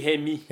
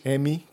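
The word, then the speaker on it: Hemme: Ça serait, en tout cas, la rumeur tourne autour de Christy M. – Christy Hemi. Hemi, que ça se prononce.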